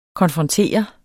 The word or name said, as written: konfrontere